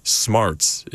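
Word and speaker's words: Smerz: It's pronounced Smarts. It's according to Google, it's pronounced exactly like Smarts.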